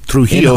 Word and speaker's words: Trujillo: Is, Trujillo.